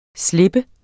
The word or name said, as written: slap